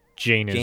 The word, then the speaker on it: Jainism: So in and around 599 to 537 BC, Jainism, a religion founded by Prince... Sorry if I mispronounced this. – I think it's Jainism.